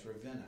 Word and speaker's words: Ravenna: And I'm just looking forward to hearing the great reports out of Ravenna, right? It's Ravenna.